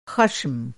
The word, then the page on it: ख़श्म: خشم